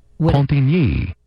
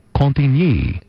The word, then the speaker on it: Cantigny: Cantigny. – What? – Oh man. – Cantigny. – Cantigny.